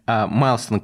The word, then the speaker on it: Millstone: Правильно у меня произношение сейчас похоже на английское? Майлстон Capital. Ну, в целом, да.